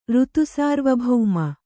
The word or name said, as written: ಋತುಸಾರ್ವಭೌಮ